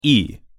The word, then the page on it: и: Russian